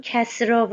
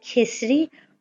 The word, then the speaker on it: کسری: تلفظ درست کسرا و کسری هر دو صحیحه درست رو شما میفرمایید ولی کسرام تلفظ کنیم ایراد نداره